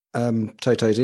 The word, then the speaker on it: Toto: Yeah, I think it was from Toto's interview. I said it right this time, Toto.